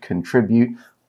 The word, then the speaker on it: contribute: Now, you may have noticed Elsie and I pronouncing that in slightly different ways contribute, contribute. Both are absolutely fine.